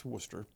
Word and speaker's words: Worcester: Worcester State, which, by the way, is spelled as Worcester, but it's Worcester.